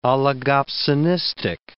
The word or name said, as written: oligopsonistic